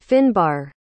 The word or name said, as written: Finnbar